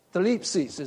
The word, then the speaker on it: Thlipsis: Thlipsis is the way it's pronounced. Thlipsis, okay? Phonetically, T-H-L-I-P-S-I-S.